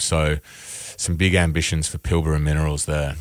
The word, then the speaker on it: Pilbara Minerals: Pilbara Minerals, well-known company. – Pilbara. Let's get some pronunciation. – Pilbara Minerals.